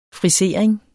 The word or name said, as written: frisering